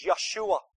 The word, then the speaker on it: Yeshua: That's just the English pronunciation. His name is Yeshua. Joshua. That's how we say Joshua, but the Jews say Yeshua.